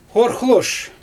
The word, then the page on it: xorxloʃ: Xyzyl